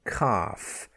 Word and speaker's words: calf: Chalk, white chalk, C-H-A-L-K, chalk. And like half, we have the young cow, calf, C-A-L-F, a newborn calf. Okay, and then before the letter M, we also have the silent L, bam, B-A-L-M, calm, C-A-L-M, the calm weather.